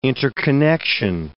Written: interconnection

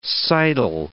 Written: seidel

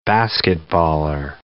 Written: basketballer

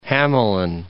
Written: Hamelin

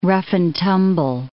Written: rough-and-tumble

